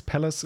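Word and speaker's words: Pvlace: Es gibt in Heilbronn ein, ähm, ein Dude, der, der nennt sich halt Palace, also PV Lace, Palace ähm, ausgesprochen,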